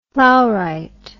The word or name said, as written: plowright